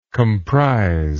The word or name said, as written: comprize